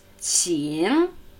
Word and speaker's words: 请: Qing, Qing.